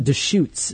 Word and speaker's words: Deschutes: I call it Deschutes. D-E-S-C-H-U-T-E-S. – Is that right, Jamie? – Yeah, that's right.